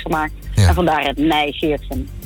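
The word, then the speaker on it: Nij Geertgen: Medisch directeur van het Centrum voor Vruchtbaarheid Nijgeertsgen. Zeg ik dat goed? – Ja, dat zeg je heel goed.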